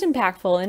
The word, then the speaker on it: Impackedful: Just Impackedful, and it's spelled a little funny. It's Impackedful. So almost like I'm packed full from food, but drop the second L.